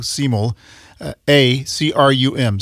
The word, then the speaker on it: Simulacrum: S-I-M-U-L, simul. A-C-R-U-M. Simulacrum is the way you pronounce it.